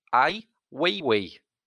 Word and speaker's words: Ai Weiwei: É AI, espaço, W-E-I, W-E-I, Ai Weiwei. Isso mesmo. É, do, é praticamente como se fala é,